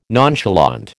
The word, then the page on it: nonchalant: American English